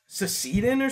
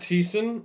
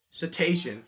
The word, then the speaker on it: cetacean: So you know the word crustacean? – Sure. – It's in the same, so it's cecetin or something? Like, it's not. – Cetacean? No. – You don't pronounce the A. It's like, you go right through it.